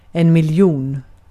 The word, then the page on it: miljon: /mɪlˈjuːn/